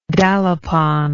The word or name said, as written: dalapon